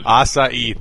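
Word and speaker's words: açaí: Acai, thank you.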